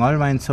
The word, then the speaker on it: Albeins: Das heißt, das beschallt das südliche Eisacktal Richtung Albeins oder so, Albes. – Genau, das hast du jetzt genau richtig gesagt. Genau, die Bühne zeigt genau Richtung Albeins hin und das sind 4 Kilometer ungefähr bis Albeins, so weit lang es nicht.